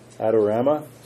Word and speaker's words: Adorama: And we're going to jump to item number 9, discussion and consideration of purchase requests to Adorama. Is that how you say it? – That's correct.